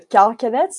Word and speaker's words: carcanet: Yeah, so I should say uh, first your pronunciation of carcanet is perfect. It's a carcanet, so it means a, a necklace, um, usually of uh, gold or set with jewels.